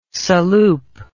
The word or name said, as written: saloop